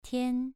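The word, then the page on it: tian: tone 2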